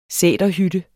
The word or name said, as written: sæterhytte